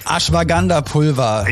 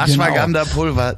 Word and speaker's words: Ashwagandha: Ashwagandha-Pulver. – Ja, Ashwagandha-Pulver, genau.